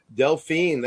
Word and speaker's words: Delphine: I'm a name butcher. Delphine? – Delphine, that's art. Yeah, I pronounced it right.